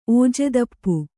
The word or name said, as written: ಓಜೆದಪ್ಪು